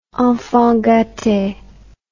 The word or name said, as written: enfant gate